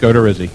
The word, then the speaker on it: Odorizzi: The Tampa Bay Baseball Writers Association Outstanding Rookie for 2014 is Jake Odorizzi.